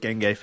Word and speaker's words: dengue: A bit of dengue fever. Is that pronounced dengue? Is that right? – Dengue.